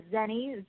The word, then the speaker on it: Zenny: Yeah, it's a Zenny, Z-E-N-N-Y. – Okay. And last name? – It's P-H-U-O-N-G, Fung.